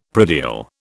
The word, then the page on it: predial: British English